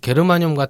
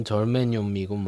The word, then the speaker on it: 게르마늄: 게르마늄 같은 경우는 정말 이건 굳이 왜 바꿨는지 모르겠는데 똑같은 이름인데 독일식으로 읽으면 게르마늄이고 네. 그렇죠. – 영어식으로 읽으면 절메늄이고 뭐 그렇습니다.